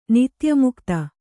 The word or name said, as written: ನಿತ್ಯಮುಕ್ತ